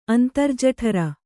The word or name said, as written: ಅಂತರ್ಜಠರ